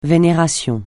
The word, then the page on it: vénération: francais